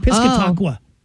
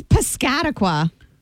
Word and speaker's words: Piscataqua: Well, we need that one, and I was butchering, how do you say, I was saying Piscataqua. Oh. – It's Piscataqua.